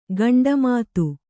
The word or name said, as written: ಕೊಂಡೆಮಾತು